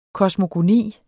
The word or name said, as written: KOSMOGONI